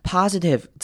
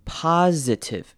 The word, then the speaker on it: positive: positive 这个字要特别注意的是中间的 i，我们可以念成短母音 i 的声音 positive，念成 i 的声音，或者是呢，你也可以念成轻音 positive，两种声音都可以。